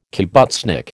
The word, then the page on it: kibbutznik: American English